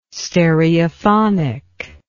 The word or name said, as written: stereophonic